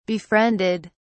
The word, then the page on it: befriended: British English